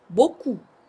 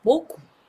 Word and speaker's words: beaucoup: Merci beaucoup。 Merci beaucoup。 Merci beaucoup。こちらの beaucoup をつけることによって、一つ丁寧な表現になります。こちらの beaucoup、非常にたくさんのといった意味があるんですけれども、たくさんありがとうということから、どうもありがとうという表現になる、 merci beaucoup。